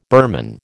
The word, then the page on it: Burman: American English